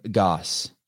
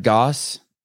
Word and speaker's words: Gosse: Gosse, rhymes with boss. – Gosse. Our guest is Brad Boss, Brad Gosse, and he is hailing from north of the United States, where I reside.